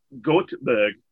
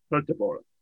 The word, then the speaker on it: Göteborg: For me saying Göteborg for all these years, not realizing the Swedish pronunciation was Göteborg.